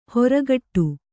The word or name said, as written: ಹೊರಗಟ್ಟು